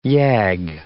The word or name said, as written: YAG